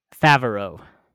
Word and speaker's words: Favreau: Jon Favreau, right? Is how you say his name? – Favreau.